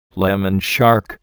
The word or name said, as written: lemon shark